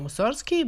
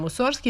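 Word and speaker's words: Musorgski: I sam Musorski je više puta insistirao na izgovaranju glasa G u njegovom prezimenu,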